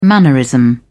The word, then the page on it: mannerism: British English